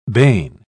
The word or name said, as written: bane